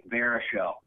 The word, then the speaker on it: Baruchel: His name's pronounced Jay Baruchel.